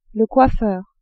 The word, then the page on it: coiffeur: female